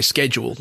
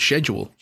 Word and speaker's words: schedule: So we would say schedule, like Americans, not schedule, like the English.